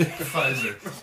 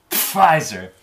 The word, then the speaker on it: Pfizer: Are we pronouncing it wrong? – It's Pfizer. – Pfizer.